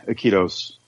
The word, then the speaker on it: Iquitos: and I think we're gonna go to is it Iquitos? Iquitos, is that how you say it? – Iquitos. – Yeah, Iquitos.